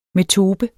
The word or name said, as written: metope